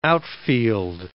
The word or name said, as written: outfield